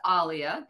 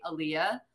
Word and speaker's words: Alia: It's actually pronounced Alia. That okay. is the correct way, but it's just been easier as a child, like growing up, for people to just say Alia.